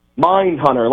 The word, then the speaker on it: Mindhunter: Nine-hunter? How do you say it? – Mindhunter, like the fantastic Netflix show.